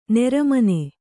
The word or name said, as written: ನೆರಮನೆ